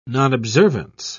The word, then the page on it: nonobservance: British English